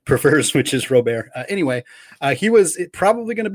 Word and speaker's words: Robert: That is how it is officially pronounced, is Robert. He has given people permission to call him Robert because people screw it up a lot.